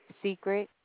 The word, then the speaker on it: Secret: Secret. – Secret. – Correct. – Spell that first name. – S-E-C-R-E-T.